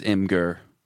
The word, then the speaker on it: Imgur: See, I always said it with Imgur because that's how it's spelled out. – Yeah. – But my friends, who are probably just idiots, always say imager. I'm like, there's no letters that make it sound like that. – I would say that it is Imgur. – Yeah. – Phonetically, it's definitely Imgur.